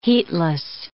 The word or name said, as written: heatless